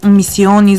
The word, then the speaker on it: Мисиони: 170 000 лева бяха събрани за започването на най-мащабните археологически разкопки в местността Мисиони, издано така ударението край Търговище.